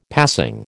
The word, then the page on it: passing: American English